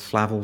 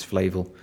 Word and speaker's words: Flavel: Flavel, depends how you want to pronounce it. I think it's Flavel.